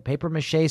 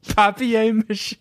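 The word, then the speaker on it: papier-mâché: how do you say it, paper mache sculpture. – No, say it your way. – I can't help it when I see the French, you know? – Papier mache.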